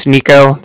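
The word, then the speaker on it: Niko: Yeah, like I said, my name's Nico. That's N-I-K-O. I'm calling from the Bank of Cardiff, and that's spelled C-A-R-D-I-F-F, as in Frank Frank.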